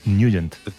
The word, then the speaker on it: Nugent: Pero la pronunciación correcta es Nugent.